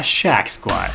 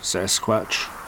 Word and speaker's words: Sasquatch: A shack squat. Shack squat? No, wait. Squash. – Oh, squash mat. Or squash. Squish? Squish sack. – Sack sash. Sash snack? – Short stack. You boys trying to pronounce Sasquatch? – That's the guy. That's what we're dealing with here. The monster is a Sasquatch.